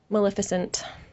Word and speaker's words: Maleficent: Is that the way you say it? – Maleficent. – Maleficent. Thank you. That is part of the way I pronounced it as a kid. – Yes, so probably because you read it and never had anybody speak it to you.